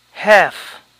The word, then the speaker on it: half: Mitad, half, half, la L no se pronuncia, half, calf.